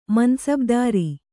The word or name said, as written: ಮನಸಬದಾರ